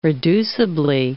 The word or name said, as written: reducibly